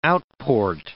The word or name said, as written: outport